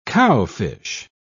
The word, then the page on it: cowfish: British English